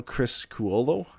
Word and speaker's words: Criscuolo: And AHLer Kyle Criscuolo, I think is how it's said, but nailed it.